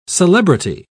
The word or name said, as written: celebrity